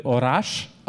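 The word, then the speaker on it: oraș: Can you do Orash? – Orash? – Yeah. – Orash? Meek. – No, I can still hear your accent. You're still not perfect.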